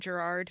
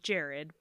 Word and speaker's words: Jared: Now, Jared Clay, and I'm actually going to apologize if I accidentally call him Gerard, because that's how his name is spelled, but I believe, and Allie and I have come to the conclusion that it's pronounced more like Jared, but I'm sorry if I say Gerard.